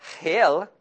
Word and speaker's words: Hiel: In 1 Kings 16, verse 34, it says, "In his days, Hiel," that's how you say it, but Hiel, kind of sounds a lot like hell, I know, "of Bethel built Jericho."